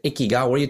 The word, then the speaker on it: ikigai: Instead, they have a word called ikigai that's spelled I-K-I-G-A-I.